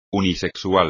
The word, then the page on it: unisexual: espagnol